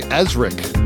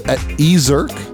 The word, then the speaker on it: Ezirk: I was going to say Ezrik, but it's Ezirk.